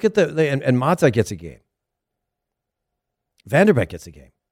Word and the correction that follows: Van de Beek: Van de Beek,